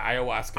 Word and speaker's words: ayahuasca: Yeah, ayahuasca.